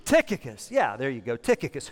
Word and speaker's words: Tychicus: Tychicus. That's how you pronounce it. Well, that's just fake it till you make it on that one, right? Tychicus.